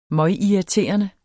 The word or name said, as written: møgirriterende